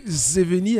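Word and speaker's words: Zjevení: Witching Hour Production réédite Zeveni, alors ça s'écrit Z-J-E-V-E-N-I.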